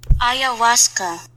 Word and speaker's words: ayahuasca: All right, I don't know the pronunciation of this word. A-Y-A-H-U-A-S-C-A. – Ayahuasca. – I'm sorry, what? – Ayahuasca. – Okay, ayahuasca.